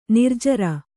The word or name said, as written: ನಿರ್ಜರ